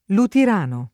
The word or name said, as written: Lutirano